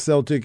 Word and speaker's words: Celtic: And it is pronounced Celtic FC.